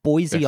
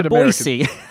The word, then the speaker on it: Boise: It's got this wonderful kind of rubberiness to the way you say the S in Boise. – Yeah, it is evocative to me of the bouncing ball used in sing along cartoons. – Yes, it's got a shuffle feel to it, right? Boise, Idaho. – You know what's quite interesting is the Wikipedia pronunciation has it with a non sibilant S. – How does that sound? – No, a sibilant S.